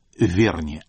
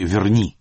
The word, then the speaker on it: Верни: А почему вы делаете ударение «Верни», а не «Верни», как ее большинство людей произносят? – Ну, потому что я привык говорить «Пикассо», а не «Пикассо». Только по этой причине. Или я говорю там «Поль Маккартни», а не «Пол Маккартни». Во-первых, это, конечно, языковая привычка.